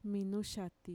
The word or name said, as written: minuxati